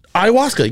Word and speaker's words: Ayahuasca: Ayahuasca? – Ayahuasca, yes.